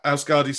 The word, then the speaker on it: Euskadi: Looking ahead, you talk about conducting your orchestra back home, and you become associate conductor of the, I don't know how to pronounce this word, Euskadi, is that how you pronounce it? – Euskadi Symphony Orchestra in around 97.